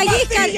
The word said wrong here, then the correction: gift card: Oye, y no se dice Giscard, se dice gift card.